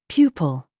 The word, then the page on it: YOU: British English